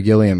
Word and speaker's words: Gilliam: But this giant stack of Terry Gilliam emails we're still getting after 149 shows, yeah. – Leave us alone. – It's a hard G.